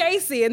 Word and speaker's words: JVCIE: It's JC. And the V is for the A. You know how Aaliyah did that whole? Yeah. So it's J-V-C-I-E because my name is spelled J-A-C-I-E.